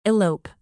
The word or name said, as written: elope